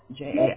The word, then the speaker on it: Jaime: Jaime.